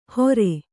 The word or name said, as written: ಹೋರೆ